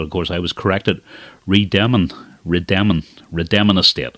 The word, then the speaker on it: Rademon: how to say that name, Rademon. Not Rademon, as I incorrectly pronounced it.